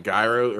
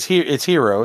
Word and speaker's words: gyros: Are you talking like gyro or gyros or whatever the fuck? – It's hero. It's pronounced hero, but you're a hero.